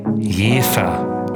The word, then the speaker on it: Jever: Eigentlich spricht man das aus Jever, also mit J-E-E-F-E-R.